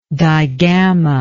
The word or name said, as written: digamma